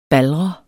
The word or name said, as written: baldret